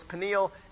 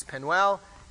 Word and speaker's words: Peniel: and the old pronunciation of Peniel was Peniel, and the newer pronunciation was Penuel, and they just put them both together.